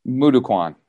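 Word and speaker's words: Moo Duk Kwan: So how different is the, I might not pronounce it properly, mukwando, you said, or? – Mudokwan.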